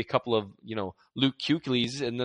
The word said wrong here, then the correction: Kuechly: Yeah, we really got to work on your non-Chief pronunciation. – Oh, Kuechly. Yeah, my bad. I only read them. I never hear them.